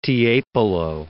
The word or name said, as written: Tiepolo